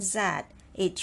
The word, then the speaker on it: 自: L, I, lie, lie, lie, second tone, lie l i li li li li going up and the next word is zi with z as a consonant and vowel i single vowel i usually it is e sound but if it is followed the consonant z it should be pronounced as u sound so in this word zi u zi with the fourth tone z z z z z.